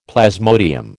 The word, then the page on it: plasmodium: American English